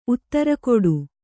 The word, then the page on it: ಉತ್ತರಕೊಡು: Kannada